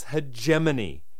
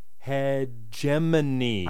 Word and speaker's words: hegemony: Words are not meant to stand up to their secondary pronunciations. It's hegemony. Hegemony.